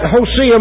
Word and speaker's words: Hosea: The actual correct pronunciation of that prophet is Hosea. So if I call him Hosea tonight, now, I'm not trying to get highfalutin, but that's just the right way to pronounce it.